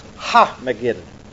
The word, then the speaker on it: Armageddon: And it says, and he gathered them together into a place called, in the Hebrew tongue, Armageddon. Now this is pronounced Ha-Mageddon, which is the hill of Megiddo.